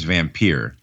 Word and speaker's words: Vampyr: And it came back as Vampyr.